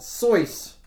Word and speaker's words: Seuss: Their family pronounces Sisal? It Soyce. – Soyce? – Rhyming with voice.